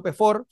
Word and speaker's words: Pefaur: Eh, a ver, bueno, el, mismo LinkedIn, Pablo Pefor, se escribe P-E-F-A-U-R, Pefaur, ahí me encuentran, ¿cierto?, para conectar.